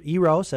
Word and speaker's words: Eros: The Eros, E-R-O-S. Pronounce it either Eros as in erotic or Eros.